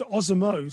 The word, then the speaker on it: Osomo: That is very close. Osomo. So I'll give you the American pronunciation.